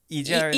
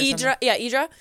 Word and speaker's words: Hydra: Yes, we went to, like, I think it's, I want to say Hydra, but I think it's pronounced Idra. – Idra.